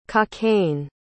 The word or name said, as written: cockaigne